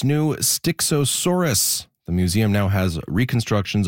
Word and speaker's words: Styxosaurus: I had to Google how to say it. It's a Styxosaurus.